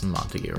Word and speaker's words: Montague: It's Montague, right? – Yeah, the Montagues and the Capulets.